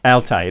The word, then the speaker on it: Altai: Altai. It is Altai. It's just like it looks.